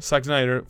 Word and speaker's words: Zack Snyder: Zack Snyder.